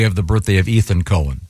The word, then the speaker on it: Cohen: Cohen. – This that's confusing because no, not when it's spelled C O H E N. – It's definitely Cohen.